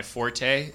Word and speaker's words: forte: Did you know it's when people say that's not my forte, it's supposed to be fort.